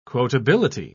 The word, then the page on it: quotability: British English